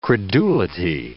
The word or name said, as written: credulity